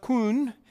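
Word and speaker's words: kun (כּוּן): The word for steadfast in the Bible, it's a Hebrew word, it's pronounced kun, and it means firm, fixed, and upright.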